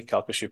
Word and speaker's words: Calcasieu: Calcasieu, and I've learned to say it properly, Calcasieu Parish, part of the Sioux, is more of a rural community.